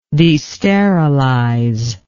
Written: desterilization

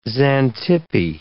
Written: Xantippe

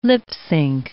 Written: lip-synch

